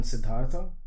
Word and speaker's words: Siddhartha: Yeah, it's like Siddhartha. I mean, it's like, so basically, the name is Siddharth. But in English, whatever, I mean, it's a God's name.